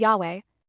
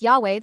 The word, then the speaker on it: Yahweh: The Bible originally had Yod He, Vav He, and scholars think the name was probably pronounced something like Yahweh.